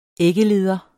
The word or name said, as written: æggeleder